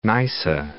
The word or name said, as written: Neisse